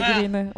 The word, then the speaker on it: Green: Enfin, bon, déjà Eva Green est française, mais que l'origine du nom est pas du tout anglaise en fait. Donc du coup, ça se prononce Green.